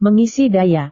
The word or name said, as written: mengisi daya